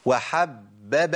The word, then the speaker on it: وحبب: يزيد ضبطي للباءات، لازم أنطق إيه؟ ثلاث باءات، ثلاث باءات. أقول إيه؟ وحبب إليكم، ما أقولش وحبب إليكم، وحبب إليكم، ما ينفعش يا شيخ، والله ما ينفع. ما ينفعش إن أنا أسقط باء من الثلاث باءات، ما هو حب باء باء، ثلاث باءات.